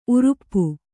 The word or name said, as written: ಊರಪ್ಪ